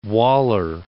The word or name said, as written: Waller